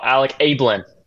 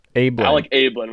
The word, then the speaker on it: Alec Abeln: Oh, Alec Abeln. – Alec Abeln.